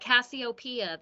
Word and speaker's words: Cassiopeia: We got Miss, how do you say her last name? Cassiopeia, like the constellation. – Yeah, like onomatopoeia. – No, like Cassiopeia, the constellation, the Greek goddess. – That's their name? – Yeah, it's a play. Cassiopeia, I think, is spelled C-A-S-I-O-P-I-A.